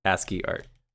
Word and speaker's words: ASCII: These are called how do you pronounce this? ASCII? – ASCII art. – ASCII art.